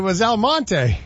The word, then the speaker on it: Almonte: I figured out what Elmont was. Exclamation point, exclamation point. – El Monte? – It was El Monte. – Really? – Oh. Yeah. So it's spelled A-L-M-O-N-T-E.